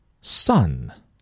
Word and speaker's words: son: Sun. 太阳。你是我的 sun, 你是我的太阳。其实这个 sun 呢，它有个同音词，是儿子的意思。 S-O-N 都读作 sun.